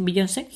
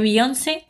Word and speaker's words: Beyoncé: que esto sí que lo voy a pronunciar bien, no voy a decir Beyoncé, porque ella sí que se lo merece, eh, que Beyoncé se merecía ganar el premio y no Taylor.